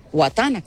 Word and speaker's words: Watana: W-A-T-A-N-A, Guatana, con W.